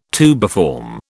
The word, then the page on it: tubiform: British English